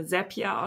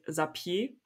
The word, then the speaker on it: Zapier: Ähm, es heißt, Selpia wird so ausgesprochen wie, wie happier. Also Sepia makes you happier ist deren, deren Slogan. Ähm, also es wird äh, äh Sepia ausgesprochen.